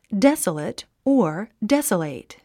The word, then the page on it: desolate: désələt; デソレト